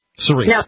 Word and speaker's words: Cerise: Charisse, you're on Free Talk Live. I hope I got your name right. – Yes, Cerise. – Cerise. – No, no H.